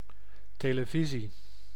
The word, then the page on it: televisie: /teːləˈvizi/; /te.le.vi.zi:/